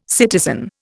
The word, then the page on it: citizen: Indian